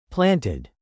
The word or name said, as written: planted